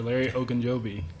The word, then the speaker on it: Ogunjobi: Sure, Larry Ogunjobi.